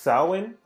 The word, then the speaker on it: Samhain: We debated on how to pronounce Samhain or Samhain, and it's pronounced Samhain, I was informed.